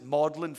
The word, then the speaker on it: Magdalen: It's spelled Magdalen, but the British pronounce it Magdalen for, I'm not sure why, actually.